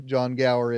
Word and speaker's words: Gower: probably even Bo Sanders wouldn't know who John Gower is.